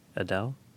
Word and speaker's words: Edel: It was directed by a guy named Uli Edel, or Edel, E-D-E-L, Adele? – I pronounce it like Eden, but with an L. Edel.